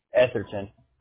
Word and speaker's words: Etherton: Etherton. – And how do you spell that? – E-T-H-E-R-T-O-N. – T-O-N.